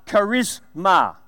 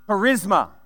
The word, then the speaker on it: charisma: In both reference to that word gift there, the Greek definition of that word is uh, charisma. Or we would say it like this charisma.